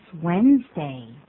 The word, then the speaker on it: Wednesday: It's Wednesday. – It's Wednesday. 可是你看它的拼法，这边我们列了出来 W-E-D, N-E-S, 再放一个 day, 那怎么不念成 Wednesday 呢？ – 其实英式发音的话比较像这个拼法 Wednesday, 我念得不好可是英式发音比较像这个拼法，美式呢就比较简单，念作 Wednesday 就好了。